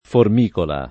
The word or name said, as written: formicola